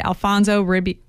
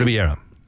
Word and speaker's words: Ribeiro: Tatiana Ali, Karen Parsons, Joseph Marcel, Daphne Maxwell Reed, Alfonso Ribby. How do you say his last name? – Ribeiro.